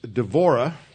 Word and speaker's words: Devorah: So I have called the lesson The Bee and the Lightning, because Devorah, which is the Hebrew pronunciation for Deborah, means bee, like a honeybee or a bumblebee, and Barak means lightning.